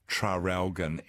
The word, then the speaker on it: Traralgon: Oh, oh yeah, and someone else, Maggie, says, don't we pronounce the first R in Traralgon anymore? Oh.